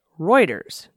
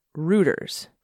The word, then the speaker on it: Reuters: The highly respected London-based news agency is pronounced Reuters, not Reuters.